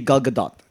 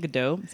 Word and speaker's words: Gal Gadot: First off, it's actually Gal Gadot. – Gal Gadot. – Gal Gadot. – I like saying Godot. Sounds nice. – I used to say Godot too. I thought it was a silent T. No, apparently it actually honestly looks like what it looks like. Gal Gadot.